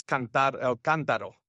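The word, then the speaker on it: Cántaro: Well, the website for the Kantaro Institute, and forgive me for mispronouncing it earlier, is I probably mispronounced it. In Spanish, it's Cantaro.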